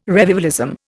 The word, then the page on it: revivalism: Indian